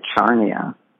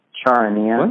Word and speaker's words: Charania: I always want to say Charnia. Charania.